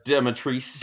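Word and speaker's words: Dimitrescu: Lady Dimitrisso or Dimitrisu? – Oh, Dimitrisse.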